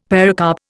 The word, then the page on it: pericarp: Indian